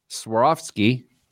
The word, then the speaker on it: Swarovski: During this year's CES, Swarovski, am I saying that right? – Yeah, that's better.